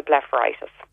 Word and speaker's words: blepharitis: So that'll benefit the blepharitis.